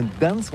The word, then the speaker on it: Gdańsk: Oui, c'est pas très facile pour des étrangers, mais remarque bien que la langue polonaise, c'est la langue où on prononce chaque lettre qu'on écrit. C'est pourquoi on dit Gdansk.